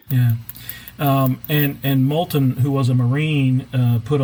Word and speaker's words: Moulton: Is it Moulton or Moulton? It's M-O-U-L-T-O-N. – Moulton, I think that's how I've heard it pronounced.